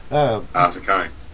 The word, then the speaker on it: Saccone: Well, guys, that was a wonderful conversation there with Representative Rick Saccone. Is that how it pronounced there? – Saccone.